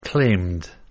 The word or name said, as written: claimed